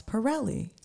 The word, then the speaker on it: Parrelli: Ross Parrelli.